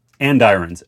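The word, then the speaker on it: andirons: And irons. And you say it like irons, but with and in front.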